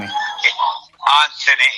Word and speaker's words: Anthony: Anthony, A-N-T-H-O-N-Y, Anthony.